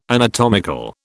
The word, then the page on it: anatomical: British English